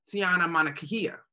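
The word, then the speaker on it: Tiana Mangakahia: But wait a minute, we've been working on how to properly say her name. Tiana Monacahia. There you go.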